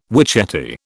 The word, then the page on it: witchetty: British English